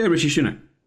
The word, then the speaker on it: Rishi Sunak: Yeah, Rishi Sunak.